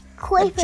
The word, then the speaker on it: Kuiper Belt: This is the Kuiper Belt, I think is how you say it. – Kuiper Belt.